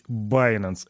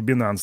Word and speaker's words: Binance: И да, правильно говорить Binance, а не Binance, так что это не ошибка, если что.